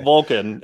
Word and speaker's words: Volcon: Vulcan, spelled V-O-L-C-O-N. So am I even saying that right? – Yeah. – Forgetting. Okay. – Vulcan.